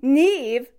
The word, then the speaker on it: Neve: Neve, pronounced like leave, means bright in Irish, and the original Irish spelling is N I A M H.